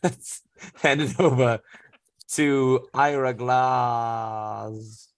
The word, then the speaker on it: Ira: It's pronounced Ira.